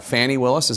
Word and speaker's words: Fani Willis: Uh, the prosecutor, uh, Fani Willis, is how you pronounce mm-hmm.